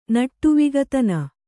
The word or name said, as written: ನಟ್ಟುವಿಗತನ